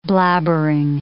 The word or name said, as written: blabbering